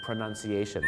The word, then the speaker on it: pronunciation: คำแรกครับก็คือคำว่า pronunciation ไม่ใช่ pronunciation นะครับถึงแม้ว่า verb เนี่ยนะครับก็คือ pronounce นะครับแต่พอมันเป็นนามเนี่ยนะครับมันก็จะพูดว่า pronunciation นี่ก็คือเรื่องแรกของเราวันนี้ครับ